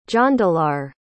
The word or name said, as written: jondalar